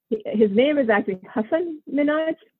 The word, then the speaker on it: Hasan Minhaj: His name is actually Hasan Minhaj. He comes from my part of the world. So we pronounce it as Hasan, but people here call him Hasan. So it's become Hasan.